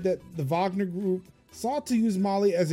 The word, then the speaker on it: Wagner: Russia established a presence in Mali last year through the Wagner Group. – Wagner Group, yeah. – Even though it's spelled with a W, but, you know, Russia.